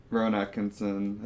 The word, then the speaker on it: Rowan Atkinson: So if I had to rank them, Martin Freeman, Rowan Atkinson.